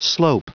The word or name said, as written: slope